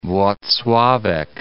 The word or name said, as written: Wloclawek